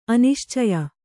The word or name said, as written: ಅನಿಶ್ಚಯ